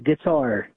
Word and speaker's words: Guattar: Guattar, just like the instrument.